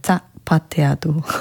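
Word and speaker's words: zapateado: 오늘도 하나 배웁니다 하셨는데 알파벳으로 하면 G니까 자파테아도라고도 많이 부르는데 이제 스페인어 발음으로는 사 약간 이렇게 발음 새는 그 TH 소리 같은 자파테아도가 맞다고 해서 네, 최대한 비슷하게 한번 해봤습니다.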